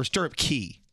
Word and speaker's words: Stirrup Cay: If you take a cruise to the Bahamas, you know, they have their own private island, Stirrup Cay or Stirrup Key. – Stirrup K. – Well, it's spelled C-A-Y, but they usually say key.